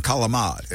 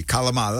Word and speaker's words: calamad: pronunciation, calamad. In Italian, the true accent, the R is a D, calamad.